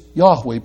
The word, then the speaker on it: Yahweh: We get that pronunciation partly from the end of Hallelujah, praise Yahweh, praise the Lord.